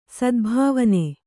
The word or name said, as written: ಸದ್ಭಾವನೆ